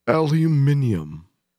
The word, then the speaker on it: aluminium: So it's aluminum. – Aluminum? Yeah. Or if you're British, it'd be aluminium.